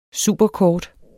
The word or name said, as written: superkort